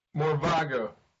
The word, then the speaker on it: Morvago: It was Morgava, right? – Morvago. – Morvago. I said it right before the call, and I messed it up now. – With a V, yeah.